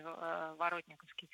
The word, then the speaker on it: Воротниковский: Его, на самом деле, очень часто и местные жители тоже называют Воротниковский, но я проверяла ударение, там, в Википедии то же самое стоит теперь Воротниковский.